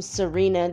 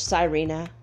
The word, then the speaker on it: sirena: Now, I think I saw someone's name Serena the other day. Serena means mermaid in Spanish. Sirena. Sirena is actually how you say it.